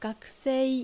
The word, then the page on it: Gakusei: Japanese